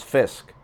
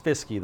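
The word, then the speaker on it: Fiske: Hey guys, I was watching a Braden Fisk highlight and the announcers pronounced it as Fisk, silent E.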